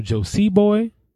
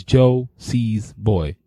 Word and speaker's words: Josiesboy: there are two S's in my name. Yeah. There's no Josie boy. There's no Josie boy. It's Joe C's boy. It's plural all the time.